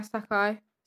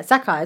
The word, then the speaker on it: Sacai: Sakai is spelled S-A-C-A-I. – Yeah, Sakai. – Sakai. Is that good? Yeah, I would say Sakai. – That's good pronunciation.